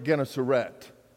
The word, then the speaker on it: Gennesaret: Jesus, when he was teaching, as Luke tells the story, he was teaching beside the Sea of Galilee, sometimes called the Lake Gennesaret.